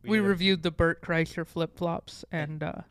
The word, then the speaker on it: Kreischer: Kreischer.